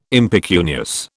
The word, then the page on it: impecunious: British English